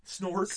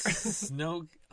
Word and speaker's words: Snoke: Snork. – Snoke.